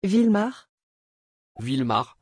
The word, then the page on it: vilmar: French